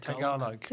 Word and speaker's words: Tagalog: It sounds like it could be the Filipino language, Tagalog, or I can't pronounce it right. So, you know, Tagalog.